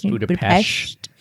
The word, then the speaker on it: Budapest: And that's how you pronounce it, right? Budapest. – I think if you're in the know, it's Budapest.